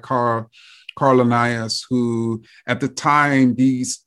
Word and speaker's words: Linnaeus: Carl Linnaeus? – Yes. – Is that correct? – Linnaeus. – I didn't butcher it.